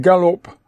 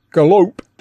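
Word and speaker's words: Galop: The tune is called Gallop, but pronounced Galope.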